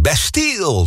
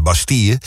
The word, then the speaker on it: Bastille: Radio 10, Bastille, zoals ze zelf zeggen. De Engelse uitspraak, want het zijn Britten. Maar eigenlijk hoor je het op zijn Frans uit te spreken. En dan is het Bastille.